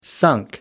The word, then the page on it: sunk: American English